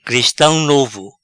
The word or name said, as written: Cristam Novo